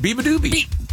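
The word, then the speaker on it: Beabadoobee: You're saying it, you're doing it right. Beebadoobie.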